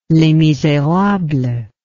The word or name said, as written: les miserables